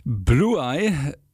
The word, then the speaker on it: Bluai: Blue Eye, jetzt wo ich es ausspreche, ergibt es Sinn. Ich dachte mir, B-L-U-A-I. Was ist das für ein Bandname? Blue Eye. Blue, blaue, okay.